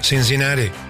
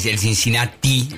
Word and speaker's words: Cincinnati: Creo que es a ver cómo lo digo, Cincinnati. – Sí, no es el Cincinnati, esa T no es como la pronunciamos Cincinnati. Nosotros en castellano.